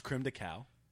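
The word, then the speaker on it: crème de cacao: I think it was creme de coco. – Yeah. – chocolate. – It's pronounced. Rim. It's pronounced creme de cacao.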